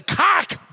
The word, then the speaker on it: Koch: And the Koch brothers, and I know everybody calls them the Koch brothers, but that's not how they pronounce it. It rhymes with sock. It's the Koch brothers. So every time you say it from now on, just remember the Koch brothers, and make sure you hit that second K, Koch brothers.